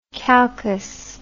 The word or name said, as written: calchas